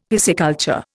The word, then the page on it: pisciculture: Indian